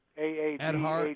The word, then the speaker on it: Aadhaar: I don't know how to pronounce it, but it's Aadhaar. – Aadhaar, yeah. – Aadhaar.